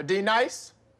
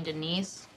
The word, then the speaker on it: Denise: Do you mean Denise? – Son of a You say your name right now. – Denise? – Say it right. – Denise. – Correctly. – Denise. – Right. – Denise. – Right. – D-Nice. – That's better. Thank you.